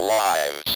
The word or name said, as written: lives